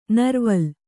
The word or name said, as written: ನೀರುವಲೆ